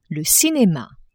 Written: cinema

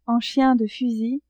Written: en chien de fusil

